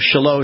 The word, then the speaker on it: Shiloh: but I always look at it and thought it was Shiloh until the mayor of Shiloh says, because I said, I know you're thinking I'm just a total goy here, because I'm not pronouncing it correctly, and he just kind of laughed, because I had this instinct that I wasn't saying it right, and he goes, no, it's Shiloh.